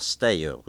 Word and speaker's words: Steiu: It was Steiu, which is spelled S-T-E-I-U, which means sort of rock or crag in Romanian, something very hardy.